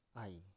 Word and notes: ai: Biak

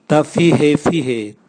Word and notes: tavihevihe: Raga

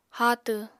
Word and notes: háatw: apatani

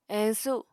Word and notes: eñsu: Apatani

